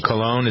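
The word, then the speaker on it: Colón: Colon. He was a hottie. – Colon, is that how you pronounce it? Yeah, he was a hottie.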